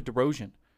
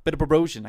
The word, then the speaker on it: Bedrosian: You started Camber DeBrosian. – Opener, baby. – Bedrosian.